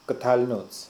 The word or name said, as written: Ktalnotz